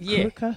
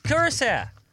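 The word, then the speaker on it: Curaçao: Curacao.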